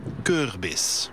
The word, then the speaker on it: Kürbis: Kürbis.